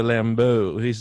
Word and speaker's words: Lambeau: Is that how his name is said? – Yes, Professor Lambeau.